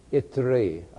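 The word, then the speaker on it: Ithrite: Then Ira, the, how do you say that? How would you say it? Itri, okay, Itri.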